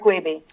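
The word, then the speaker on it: Wiebe: Both Binney and Weeb came, or how do you say his name, Weeby? – Kirk Weeby.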